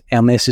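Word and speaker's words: Hermès: I don't even know how to pronounce it. Hermes is probably the French pronunciation, uber luxury fashion brand.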